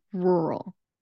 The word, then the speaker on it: rural: And so our ninth word is going to be rural. Rural is spelled with two R's. It is RURAL. And that two R sound is very difficult for people to pronounce.